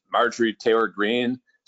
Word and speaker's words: Marjorie Taylor Greene: And Georgia's got to be the most messed up state politically in the country when you think about people like, what's Marjorie Taylor Greene?